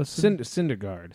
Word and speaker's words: Syndergaard: Syndergaardner? Noah Syndergaard.